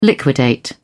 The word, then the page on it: liquidate: British English